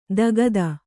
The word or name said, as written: ದಗದ